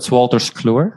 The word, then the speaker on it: Wolters Kluwer: Your first major role was as an IT consultant in, is it Wolters Fluma? Is that a bad pronunciation? – No, it's Wolters Kluwer.